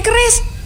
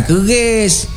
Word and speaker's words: QRIS: Bisa ya bayar pakai kris? Kok malah kayak mau bela diri aja? – Kris, Bu. Kris. Q-R-I-S. Dibacanya kris, bukan keris.